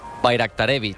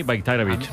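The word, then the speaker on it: Bajraktarević: Bayraktarevic. – Bayraktarevic. O sea, la J la conviertes en una I, Bayraktarevic.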